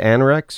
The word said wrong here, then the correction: Ahrex: hook? A-Rex. Thank you. – A-H-R-E-X.